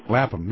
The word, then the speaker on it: Lapham: Lapham, yeah.